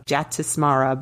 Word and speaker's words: Jatismara: During my research, I learned that the Sanskrit word, this is just kind of an aside, and I'm gonna spell it and then try to say it, J-A-T-I-S-M-A-R-A, Jatismara, means those who remember a past life.